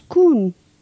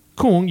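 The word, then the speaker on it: Kung (กุ้ง): Kung. – Kung, yes. You did have the pronunciation right.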